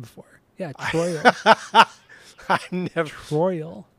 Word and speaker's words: Troyal: No, he was, he was born Troyal Garth Brooks. So his first name is spelled T-R-O-Y-A-L.